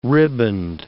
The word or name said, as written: riband